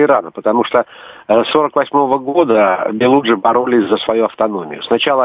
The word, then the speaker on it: Белуджи: Вот как раз о Белуджах мы сейчас и будем говорить, если я правильно поставил ударение. – Да, правильно, Белуджи.